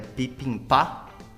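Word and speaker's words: pipimpá (비빔밥): E, e no Bapzip eles fazem, eles têm também o bibimbap, né? Que é como se fosse um mexidão de arroz, né? Que a, a pronúncia certa é pipimpá.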